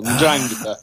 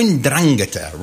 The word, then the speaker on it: Ndrangheta: Oh, so you definitely do the N then? – Oh, very much so. Yeah. Yeah. And the accent is on the second syllable. Ndrangheta. – Oh, Ndrangheta.